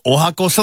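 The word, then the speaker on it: 十八番: 18 番と書いておはこと読む。 – スマクの純也さん、 31 歳の男性からは、僕のカラオケ 18 番おはこソングはこの歌です。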